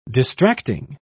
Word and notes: distracting: British English

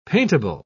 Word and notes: paintable: British English

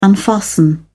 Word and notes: unfasten: British English

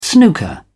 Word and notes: snooker: British English

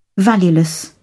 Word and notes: valueless: British English

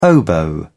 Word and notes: oboe: British English

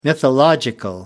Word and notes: mythological: American English

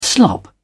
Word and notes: slop: British English

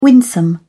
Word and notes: winsome: British English

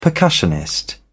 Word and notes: percussionist: British English

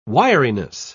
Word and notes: wiriness: British English

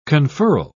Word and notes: conferral: British English

